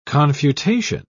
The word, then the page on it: confutation: British English